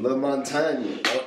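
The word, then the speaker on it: LaMontagne: Ray Lamonatone. – You get what I'm saying. – I have no idea who this is or what song this is. – Hit us with a quick preview. – La Montagne.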